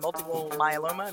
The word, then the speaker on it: multiple myeloma: One episode I listened to about a gentleman who had multiple myeloma.